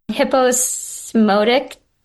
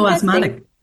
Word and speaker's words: hyposmotic: Hyposmotic swelling. – Hyposmotic.